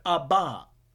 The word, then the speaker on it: Abba: Some pronounce it Abba, but it's an Aramaic word, Abba.